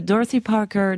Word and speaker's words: Dorothy Parker: Alors, en 1956, Dorothy Parker donne une interview au Paris Review.